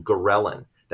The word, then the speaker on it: ghrelin: There's an increase in a specific hormone called ghrelin, that's spelled G-H-R-E-L-I-N.